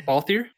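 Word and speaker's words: Balthier: And then I had, I think, a mixture between Vaughn and Baltier for DPS. I probably said that wrong. Baltier? – I won't judge you. You're right.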